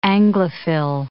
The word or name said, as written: Anglophil